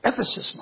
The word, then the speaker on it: Ephesus: It's called Ephesus. I'm not going to pronounce it. Ephesus, Mom.